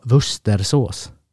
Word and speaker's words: Worcestershiresås: Shire är det inte higher. Ja, Shire. Ja. Sa jag inte Shire? Nej, du sa higher. Aha. – Men rätt ska vara Wustersås.